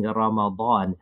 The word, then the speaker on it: Ramadan: Hence, they will make the Baad very distinct by saying Ramadan.